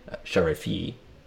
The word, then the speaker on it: Sharifi: Sharifi. Did I get that right? – The extra E sound at the end, yes.